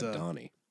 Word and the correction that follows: Aldhani: Yeah, I think it's Aldhani. So it's kind of like a roll the D, man. I mean, don't be weird about it or anything, but just roll the D.